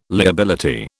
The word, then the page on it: liability: British English